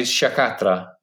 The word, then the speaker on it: Sciacchetrà: The Cinque Terre Sciacchetrà DOP 2018 was awarded the best sweet wine, or the miglior vino dolce.